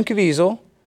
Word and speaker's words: Caviezel: Caviezel, am I saying that right? How do we say his name? – Jim Caviezel.